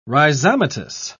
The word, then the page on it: rhizomatous: British English